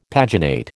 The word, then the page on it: paginate: American English